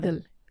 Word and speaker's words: Kaathal: Kadal.